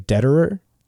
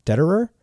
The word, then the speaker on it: Dederer: Dave Dederer. Dederer. – Okay. – D-E-D-E-R-E-R.